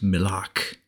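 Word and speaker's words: milak (מלח): The word for salt is pronounced milak and comes from the word pronounced malak, which means to tear away or to vanish.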